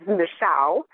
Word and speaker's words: Mixhau: It's actually no one knows how to pronounce it or spell it, or it's Mixhau.